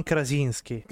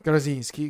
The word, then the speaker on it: Krasinski: John, John, Krasinski. Krasinski, grazie, gentilissimo.